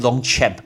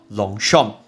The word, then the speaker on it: Longchamp: 不要再念 Longchamp，不要乱念，那是法文的发音叫 Longchamp。